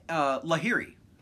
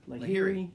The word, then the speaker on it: Lahiri: Harold Varner III, Mark Leishman, Neiman, and Lanhart? How do you say that guy's name? Right here. – Lahiri. – Lahiri, yep.